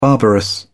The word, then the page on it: barbarous: British English